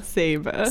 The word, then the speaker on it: Saba: Saba.